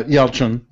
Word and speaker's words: Yelchin: Yeah, Yelchin.